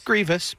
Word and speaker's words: Grievous: General Close. – Grievous? – Grievous. But yeah. – Grievous? – Yeah, you had an extra syllable.